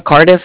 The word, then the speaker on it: Cardiff: Se llama Cardiff, se escribe como C-A-R-D-I-F-F.